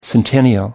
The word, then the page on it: centennial: British English